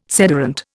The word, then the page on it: sederunt: Indian